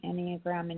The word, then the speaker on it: Enneagram: I want to encourage you to look up the Enneagram and take the test and learn more about yourself.